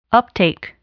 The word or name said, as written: uptake